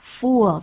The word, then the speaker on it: fool: 对，而且像 Peter 刚讲的，注意那个母音，不要念作 u fool，就是个傻子，一个呆子。 Fool，而且它比较长，母音的部分比较长。 I'm a fool. I'm a fool 跟 I'm full 发音不一样。